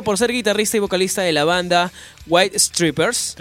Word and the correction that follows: White Stripes: Strips, ¿cómo se dice? Stripes. White Stripes.